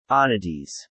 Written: oddities

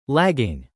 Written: lagging

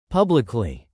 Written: publicly

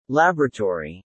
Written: laboratory

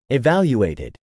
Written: evaluated

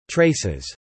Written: traces

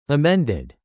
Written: amended

